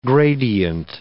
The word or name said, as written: gradient